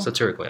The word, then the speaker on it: satirical: Am I pronouncing it right? – Satirical? – Satirical, yeah.